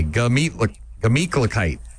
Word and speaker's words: Gemütlichkeit: Not a gla. Just G-A. Gemütlichkeit.